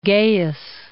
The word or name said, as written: Gaius